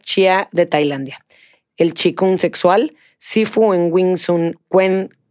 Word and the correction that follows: Wing Chun: Kung Fu, ¿lo dije bien? Wing Chun Kung Fu, dentro del linaje del Sifu Carson Lau.